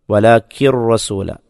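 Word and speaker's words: വലാക്കിർ റസൂല (ولكن رسول): വലാക്കിൻ എന്നതിലെ ആ നൂനിൻ്റെ ഉച്ചാരണം, സാക്കിനായ നൂനിൻ്റെ ഉച്ചാരണം അവിടെ കാണപ്പെടുകയില്ല. ആ നൂനിനെ റായിലേക്ക് ചേർക്കുകയാണ് ചെയ്യുന്നത്, ഇദ്ഗാം ചെയ്യുക എന്ന് പറയും. അപ്പോൾ അവിടെ സാക്കിനായ നൂനിൻ്റെ ഉച്ചാരണം കാണപ്പെടില്ല. വലാക്കിർ റസൂല, വലാക്കിർ റസൂല,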